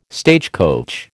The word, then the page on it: stagecoach: American English